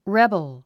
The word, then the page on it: rebel: rébəl; レバル